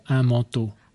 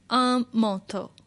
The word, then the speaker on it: manteau: Un manteau. – Un manteau. – M A N T E A U. Yeah. – It, it kind of rhymes with chapeau.